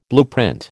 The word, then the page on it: blueprint: American English